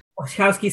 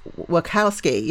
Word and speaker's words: Wachowski: So you've gone from like emotional content to, you know, how do you say their name? Wachowski? – Wachowski sisters, Wachowski. – Wachowski, yes.